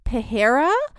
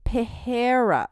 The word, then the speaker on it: Pereira: Actually, so my apologies, the correct way to pronounce his last name is Pereira. So Pereira, yeah, okay. So I realized that I was mispronouncing it over our past episodes, so I just wanted to correct myself there.